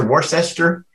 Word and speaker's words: Worcester: Worcester? – Worcester.